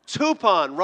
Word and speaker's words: Tupon: Tupon rhymes with coupon.